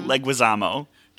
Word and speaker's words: Leguizamo: Why can I never pronounce his last name? – Leguizamo.